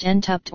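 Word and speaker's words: Šentrupert: Entrupert, pronounced Entupt or Entupt, German, Sankt Ruprecht, is a village in the traditional Lower Carniola region in southeastern Slovenia.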